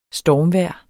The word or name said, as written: STORMVEJRETS